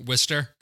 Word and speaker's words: Worcester: Like really, Gloucester, Worcester.